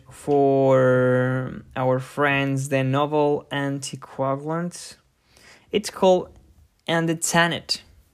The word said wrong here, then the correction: andexanet: Dear guest, can you check we're saying this right? – Andexanet.